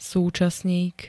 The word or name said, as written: súčasník